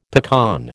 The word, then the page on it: pecan: American English